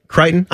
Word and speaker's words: Crichton: They tried to reboot ER and bring it back, but Michael Crichton of Jurassic Park fame. – Mm-hmm. How do you pronounce that guy, that name? – Crichton. – I honestly didn't know.